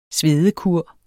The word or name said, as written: svedekur